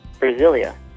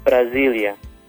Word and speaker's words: Brasília: The next city is Brasília. Brasília. Brasília. You'll notice the S, it sounds more like a Z with the Portuguese pronunciation. That is because it's between two vowels.